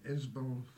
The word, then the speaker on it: Ysbal: Now, Isbel, it's not Isabel. – No. – In Manx, it's Isbel, and it's spelled Y-S-B-A-L.